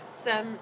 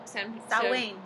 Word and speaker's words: Samhain: Samhain. – Samhain.